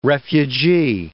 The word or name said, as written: refugee